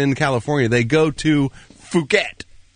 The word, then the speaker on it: Phuket: How do you say it? Phuket? – Right? – Thailand. – Oh, yeah. It is Phuket, yes.